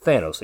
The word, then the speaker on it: Thanos: Um, Thanos is is obviously pronounced Thanos. It comes from Thanatos listen to a lot of your people on podcasts, they say Thanos.